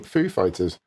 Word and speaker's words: Foo Fighters: Christopher Walken returned to Saturday Night Live this past weekend to take another stab at pronouncing Foo Fighters. Back in 2003, the Pulp Fiction star introduced the band on SNL, putting the emphasis on fighters rather than Foo.